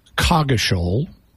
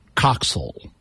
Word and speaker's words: Coggeshall: And according to what I was able to find, there are two modern English pronunciations in England for this name. One of them is it's pronounced Coggeshall. – Okay. – The other is it's pronounced Coxall.